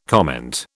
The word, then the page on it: comment: British English